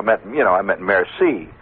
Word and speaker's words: merci: Or I'll say a merci. That's pretty good. I just learned last year that the C is pronounced that way. I used to say murky.